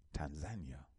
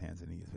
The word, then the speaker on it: Tanzania: $250 an acre in Tanzania. Some people pronounce it Tanzania, it's Tanzania.